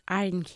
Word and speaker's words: aren't: Those aren't his. – Those aren't his. No aren't. Aren't. Una sílaba.